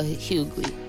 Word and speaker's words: Hughley: Hughley. How do you say his name? – Hughley. Oh, D.L. Hughley? – Yeah, him.